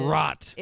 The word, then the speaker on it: wrought: Rot. – It's rot. – Let's spell that. W-R-A-U-G-H-T. – W-R-O.